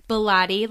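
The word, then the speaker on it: Bellotte: So that's K-A-T-Y. Bilotti, like Pilates. I always say that because everyone always pronounces it wrong. So that's B-E-L-L-O-T-T-E.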